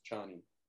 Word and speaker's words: Chani: So I'm looking at the Wikipedia, it says, yes, it says, it's pronounced as in China, so it's Chani.